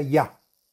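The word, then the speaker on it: Ya (יה): Y deberá meditar el santo nombre de Yud Hei, que se pronuncia Ya, bueno, ahora prosigamos y hagamos la explicación del texto del Salmo número 96.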